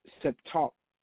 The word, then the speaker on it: Septuagint: I just mangled that word, but that is the name of the actual Greek version of the Bible is Septuagint, and I believe that is pronounced Septuagint.